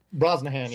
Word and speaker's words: Brosnahan: Brosnahan, yeah.